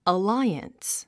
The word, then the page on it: alliance: əláiəns; アライアンス